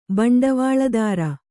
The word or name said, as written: ಭಾಂಡವಲದಾರ